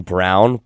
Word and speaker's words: Braun: And also Christian Brown, but he's good. – It spells out Braun, but it's pronounced Brown, which I don't understand.